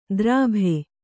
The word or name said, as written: ದರ್ಭೆ